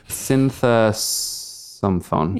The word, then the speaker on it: Sinthasomphone: Syntha some phone. – You got it.